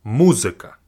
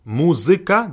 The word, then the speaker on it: музыка: But in English, we say mu, music, but in Russian, it's just simply mu, музыка. The zik sound, music, музыка, the zik sound is kind of the same. But the first syllable is not mu in Russian, it's mu, музыка, музыка, like that.